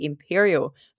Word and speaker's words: Empyreal: First up, we have a brand new third-person action RPG, Imperial, but it's spelled E-M-P-Y-R-E-A-L.